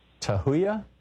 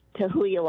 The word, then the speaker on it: Tahuya: Tahuya, Washington. Am I saying it right? – Hi, yes. Tahuya, Washington.